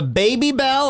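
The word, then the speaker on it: Babybel: This one is the Baby Bell.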